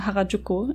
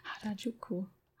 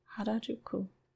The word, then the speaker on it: Harajuku: Und der Hauptladen ist in äh, Harajuku in Tokio. Das, die, die, die, die Aussprache war gerade ganz furchtbar von mir. Ich muss gerade innerlich ein bisschen weinen. Harajuku, Harajuku. Ha, ja, ihr wisst, was ich meine.